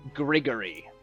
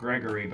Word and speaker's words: Grigori: Goes by the name Grigori. – not Gregory, but Grigori. – G-R-I-G-O-R-I. – Grigori. Or Grigori. – Yeah, he pronounces it Grigori, but we can call him whatever we want.